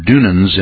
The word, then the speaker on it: Dunans: It's spelled D-U-N-A-N-S.